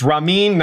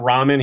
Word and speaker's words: Ramin: It's Ramin, not ramen. He's not noodle. He's not noodle soup.